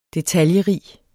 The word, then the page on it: detaljerig: deˈtaljə-